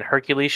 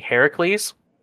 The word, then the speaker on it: Heracles: So, for example, Socrates should be Socrates, and Hercules should be Heracles.